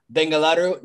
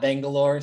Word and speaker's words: Bengaluru: Hope to see you in person at some point, whether it's in Spain, the United States, in Bengaluru, not Bangalore. Say it correctly like the local people do.